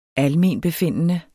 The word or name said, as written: almenbefindende